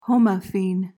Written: homophene

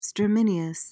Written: stramineous

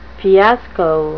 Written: fiasco

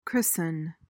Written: christen

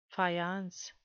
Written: faience

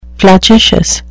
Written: flagitious

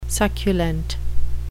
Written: succulent

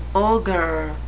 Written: ogre

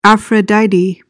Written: aphrodite

